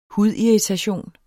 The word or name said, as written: hudirritation